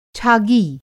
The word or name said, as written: छागी